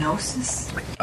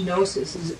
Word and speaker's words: gnosis: Kenosis is spelled in English with a K, K-E-N-O-S-I-S. Gnosis is actually spelled with a G, but you don't pronounce the G, G-N-O-S-I-S.